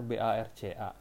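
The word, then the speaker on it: Barça: Oh iya, buat kalian yang bukan penggemar Barcelona, Barca itu kan tulisannya BARCA, itu dibacanya Barca ya, bukan Barca, tolong ya.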